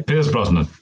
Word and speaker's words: Pierce Brosnan: We have Pierce Brosnan. – Yeah, Pierce Brosnan.